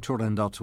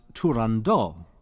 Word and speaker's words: Turandot: Perhaps here I should explain why I've been pronouncing Turandot with that final T. It's really up for grabs. Some people think it should be pronounced Turandot, but that is Frenchified, and I don't know of any proof that Puccini or his associates used that pronunciation. On the other hand, when a high note ends on the final syllable of the name Turandot, then singers will drop the T, which may be what Puccini intended.